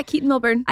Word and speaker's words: Keaton Milburn: Yeah, Keaton Milburn.